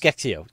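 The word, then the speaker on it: SCExAO: So say we bolt that upgraded version of Sphere, or how do you pronounce it? Skeksio. – Yes. – Skeksio.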